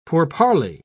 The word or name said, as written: pourparler